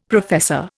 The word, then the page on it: professor: Indian